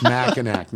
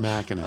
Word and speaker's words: Mackinac: And then he simply writes without pronunciation, it's Lake Orion, not Lake Orion. It's Novi, not Novi. It's Mackinac, not Mackinac.